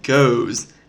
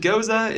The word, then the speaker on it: Gose: Goes is probably what you were thinking. G O S E. And you're wondering, how in the world do I pronounce this? Is it goes? Is it goza?